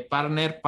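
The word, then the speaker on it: P4rtner: Lo que sí es que para que se mantengan al tanto, sigan las redes de Partner, partner.me con la A de Partner como número 4, se escribe P4 Partner,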